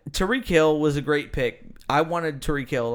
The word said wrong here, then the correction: Tyreek Hill: like a motherfucker. He took him. – Isn't it Tyreek Hill? – I don't care. Tyreek.